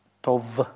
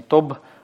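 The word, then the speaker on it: тов (טוֹב): Тов. Потому что в букве «бет» у нас нет дагеша, да, поэтому не смычно произносится не «тоб», а «тов» щелевой. Да.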